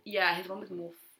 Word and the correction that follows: Morphe: is really cool. – Morphe. – It's literally Morphe. – Well, it's just M-O-R.